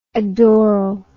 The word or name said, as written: adorally